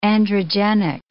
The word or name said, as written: androgenic